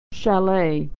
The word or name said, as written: chalet